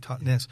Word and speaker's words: Totnes: Totnes, Totnes, as you say it properly, Totnes.